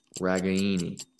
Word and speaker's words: Ragaini: So the proper way is Ragaini. Ragaini. You got to add a little spice to it.